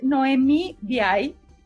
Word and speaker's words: Nohemí: Ella es Noemí Diay. ¿Está correcto, Noemí? ¿Está bien, pronunciado tu apellido? Sí, es correcto.